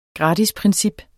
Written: gratisprincip

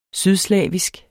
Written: sydslavisk

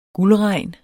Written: guldregn

